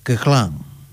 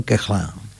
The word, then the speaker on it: Koechlin: Bien, y otro músico francés, maestro de Émile Goué, fue, o es, Charles Koechlin. Así lo pronuncian los franceses, me pareció escuchar. Koechlin sería, ¿no? Bueno, los franceses le dijeron Koechlin.